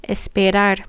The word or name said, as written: esperar